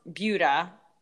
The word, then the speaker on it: Buda: So my first year that I had Gilly, I was a sophomore in college in Texas, and there's this little town, pronounce Buda, spelled B-U-D-A, but it's pronounced Buda.